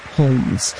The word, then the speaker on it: Holmes: Originally pronounced her last name like Sherlock Holmes, but Frenchified it to Holmes after becoming a citizen of France at the age of 24.